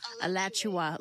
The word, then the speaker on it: Alachua: Alachua, like Joshua, but Alachua.